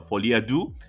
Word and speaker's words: Folie à Deux: Pero sí, no, está bien pronunciado, Folie à Deux, porque muchos dicen Folie à Deux, Folie à Deux.